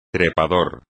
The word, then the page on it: trepador: espagnol